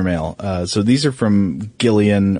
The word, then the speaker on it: Gillian: How do you pronounce that name? – I think it depends because the way that I always heard Jillian Anderson, which I believe is how she pronounces it, but then the woman from Community, it's spelled the same. Hers is pronounced Gillian.